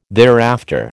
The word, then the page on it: thereafter: American English